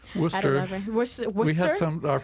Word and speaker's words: Worcester: It's not spelled Worcester, no, but it's it is not. pronounced that way.